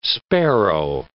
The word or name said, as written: sparrow